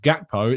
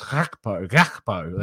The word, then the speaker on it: Gakpo: Priyank says, yesterday our scouting Gakpo, who now I've learned is not pronounced Gakpo, it's pronounced Gakpo. That's what they were saying on the commentary. But I know it's a lot easier to say Gakpo, but I can't redo the huh sound.